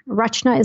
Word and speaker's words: Rachna: Rachna is spelled RACHN as in Nancy, APATEL.